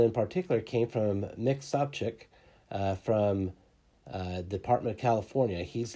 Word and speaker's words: Sobczak: That's Mike Sopcich, and his last name is spelled S-O-B-C-Z-A-K. And let me correct myself. It's Mick Sopcich, M-I-C-K, last name S-O-B-C-Z-A-K.